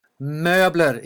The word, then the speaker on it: Möbler: Möbler. It's a Ö. It's a O with two dots.